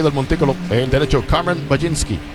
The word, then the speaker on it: Mlodzinski: Majinski, que para nada se deletrea en cómo se pronuncia. Y miren cómo se deletrea: M-L-O-D-Z-I-N-S-K-I. Pronunciado Majinski.